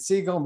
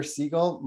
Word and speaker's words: Siegel: Just type in David Siegel, Meetup. You'll find me. I'll accept your invite because I love linking with people. That's great. Twitter, at David Meier Siegel. My middle name is spelled M-E-I-R, last name Siegel, S-I-E-G-E-L.